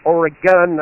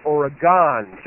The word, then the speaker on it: Oregon: And secondly, Oregon is pronounced Oregon, not Oregon.